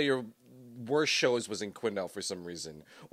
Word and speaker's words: Quesnel: It's the way I was gonna call it Quesnel. – That's what it is. – Yeah. – There's a silent S in there.